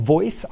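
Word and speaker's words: VOYC: Yes, it's pronounced VOICE, standing for voice of your customer.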